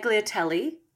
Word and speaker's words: tagliatelle: Is it tagliatoni? – Tagliatelle? No, tortelloni? – No, what? Tagliatelle. The thick noodles, bolognese.